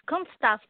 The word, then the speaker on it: Kunststoff: In the Bay Area, you perform with, I don't know how to pronounce this, K-U-N-S-T? – Say that again, please. – How do you say K-U-N-S-T and then hyphen S-T-O-F-F. – Kunststoff Dance Company.